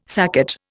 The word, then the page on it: saccate: Indian